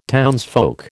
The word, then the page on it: townsfolk: American English